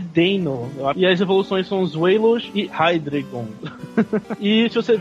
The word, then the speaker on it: Zweilous: Esse que você tá falando, eu tô vendo aqui o nome. É Deinos, Wailos e Hydreigon.